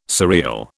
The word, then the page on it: surreal: British English